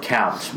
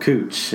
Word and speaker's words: Couch: There's a street in Portland called, it's spelled couch, but it's called cooch.